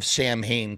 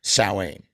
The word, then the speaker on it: Samhain: Because if you watch any videos on YouTube about any information about this band called Samhain, you will always find somebody in the comments trying to correct you with the real name is actually pronounced Samhain, which is like the Celtic original way of saying things, when in fact every member of Samhain calls the band Samhain.